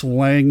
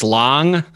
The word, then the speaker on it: Lang: What is the correct pronunciation? – You know, I'm a hypocrite. I alternate because I just Americanize my accents pretty quickly without thinking about it. It is Long.